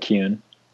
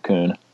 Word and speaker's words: Kuhn: My mother pronounces it Kuhn. – Oh, see? – Yeah, I grew up in Virginia, so I pronounce it Kuhn.